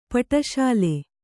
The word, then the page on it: ಪಟ್ಟಶಾಲಿ: paṭṭa śali